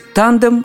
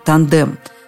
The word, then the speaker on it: тандем: Можно говорить «тандем», а можно «тандем». Откуда вариант тандем, понятно, так ближе к языку-источнику, то есть к английскому, где э, тандем или тандем вообще произносится как тандем и ударение на первом слоге.